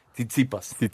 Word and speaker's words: Tsitsipas: Titsipas, Y es el griego que eliminó a Roger Federer el torneo pasado.